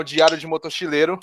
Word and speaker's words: Diário de Motochileiro: O Alfredo, nada mais, nada menos, que é o dono do canal Diário de Motochileiro.